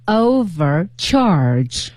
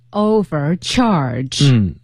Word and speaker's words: overcharge: 那么它的这个读音就是 overcharge，重音在后面。 – 对， overcharge。